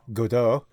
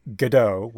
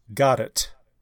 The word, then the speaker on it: Godot: In the UK, it is pronounced Godot, with the accent on the front. In French, it is an unaccented language, so you would say Godot.